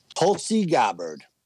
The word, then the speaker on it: Tulsi Gabbard: 1599, Tulsi Gabbard. – I can't believe you said her name right.